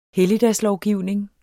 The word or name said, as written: helligdagslovgivning